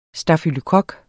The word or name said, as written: stafylokok